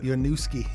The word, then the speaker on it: Winooski: Is that how you say it? Winooski. Yeah, they're right outside of Burlington.